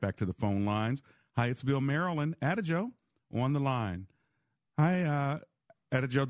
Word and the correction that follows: Adjoa: Did I say your name right? – It's Adjoa. – Adjoa. I like it. Adjoa. Yes. It's much prettier the way you say it, too.